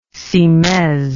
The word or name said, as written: cimaise